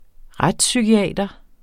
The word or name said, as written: retspsykiater